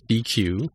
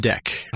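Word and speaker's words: deque: Because now they've got this DQ, or pronounced DEC. I read it as DQ, but DEC, that basically allows you to enter things into arrays.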